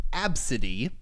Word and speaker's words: Abcde: The daughter's name, spelled A-B-C-D-E, pronounced Absidy, and her mother were catching a flight to El Paso, Texas, a few weeks ago when the incident happened.